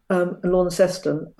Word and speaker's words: Launceston: Originally, they were coming to both Sydney, Botany Bay, and Hobart, but they also were deporting people through Launceston, as they pronounced it, Launceston, as you would in Cornwall, Launceston, up the River Tamar.